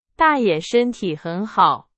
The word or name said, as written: 大爷